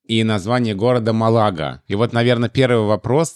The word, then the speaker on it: Малага: Ну и Малага, да, то же самое. Правильно, Малага. И я надеюсь, что в русских словарях будет Малага тоже.